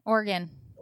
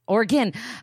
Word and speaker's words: Oregon: How do you say it? Oregon or Oregon? – Oregon. – Oregon.